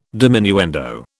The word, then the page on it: diminuendo: British English